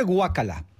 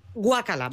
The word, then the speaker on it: guácala: Se dice guacala. – Guácala, lo he dicho mal.